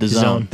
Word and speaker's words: DAZN: Is that how you say it? – The Zone. We've done this 100 times.